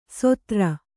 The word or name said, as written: ಸೋದರ